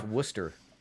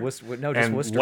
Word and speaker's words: Worcester: So you're running around Worcestershire, and you're running around like... – you know, that's pronounced Worcester. – Worcestershire. – Worcester.